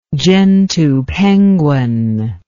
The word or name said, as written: gentoo penguin